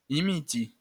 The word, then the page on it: imiti: Esperanto; imit • i